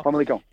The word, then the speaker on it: Famalicão: No sé si es Famalicão, Famalicão. – Sí. Famalicão. Haces muy bien.